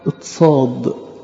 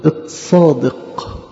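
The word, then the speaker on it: اتصاد (צדי): حرف الصاد في اللغة العبرية يتنطق اتصاد، وبمرور الوقت بقوا اليهود ينطقوها اتصادق.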